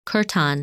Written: Chertan

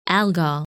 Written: Algol

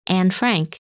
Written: Annefrank

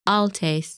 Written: Altais

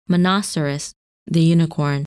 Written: Monoceros

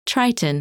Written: Triton

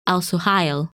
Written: Al Suhail

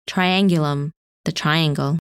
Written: Triangulum

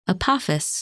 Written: Apophis

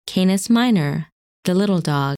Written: Canis Minor

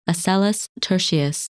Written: Aselleus Tertius

